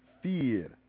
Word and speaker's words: vier: V-I-E-R. – Fear. – But the V is pronounced F.